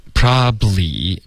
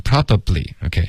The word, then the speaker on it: probably: OK， probably 不要发成 probably，OK，、 okay? 用它的这个缩写的发音 probably。 OK，两个音节就够了。